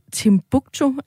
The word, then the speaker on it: Timbuktu: Og mens butleren øh, sendes til Timbuktu, er det sådan, man udtaler det land? Yes.